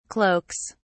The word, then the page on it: cloaks: British English